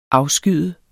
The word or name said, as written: afskyd